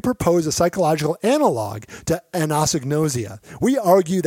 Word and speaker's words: anosognosia: Did I say that right? Anosognosia? – Very anosognosia. Close. Yeah.